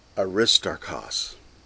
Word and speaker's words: Aristarchus: So first, Aristarchus. That's technically the way to pronounce that name, although like Tychicus, it's like Tychicus, it's easier to pronounce it the way it looks, which is Aristarchus.